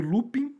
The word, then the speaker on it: Lupin: É, tá na Netflix, o pessoal fala, é, se escreve Lupin, mas como é francês, é Lupin a pronúncia, né?